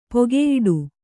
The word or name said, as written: ಪೊಗೆಯಿಡು